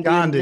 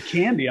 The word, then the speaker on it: Gandhi: You know, the amazing Indian Gandhi. – Leader. – Gandhi. That's how we would pronounce it here. – Okay. – But you said candy.